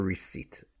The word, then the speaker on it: receipt: You go into the shop, you buy a product, you get your receipt. R-E-C-E-I-P-T, but it's silent P, so it sounds like R-E-C-E-I-T, receipt. Yeah, don't sound the P, receipt, receipt.